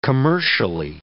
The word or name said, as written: commercially